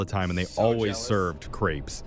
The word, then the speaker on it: crepes: I think they're called crepes. – No, they're crepes. He actually said it right.